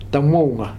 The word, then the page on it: Tamouga: Futuna